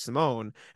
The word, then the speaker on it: Simone: I'm talking like Chris Dunn and Simone Fontecchio, which I really don't want them to trade for Fontecchio, because he pronounces his first name wrong, because it's spelled the same as my last name, but he says it differently than I say my last name, which is Simone.